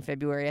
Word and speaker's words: February: I also refuse to pronounce the second R in February.